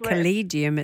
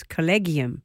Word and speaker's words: collegium: Now, I got it wrong. I said collegium, it's collegium.